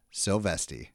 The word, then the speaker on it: Silvesti: So welcome, my friend, the personal trainer, Matt Silvesti. – Ba-boom. I'm pumped. I got Matt. How do you say it? Silvesti? – Silvesti. Yes, sir.